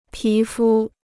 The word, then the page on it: 皮肤: pí fū